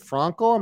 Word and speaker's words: Frankl: And you're the grandson of the great Viktor Frankl. Is it Frankl? Am I saying it right? – Frankl, yes, Frankl.